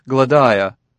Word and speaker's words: Gladiah: Her name is Gladiah." Gruer pronounced it in three syllables, accenting the second.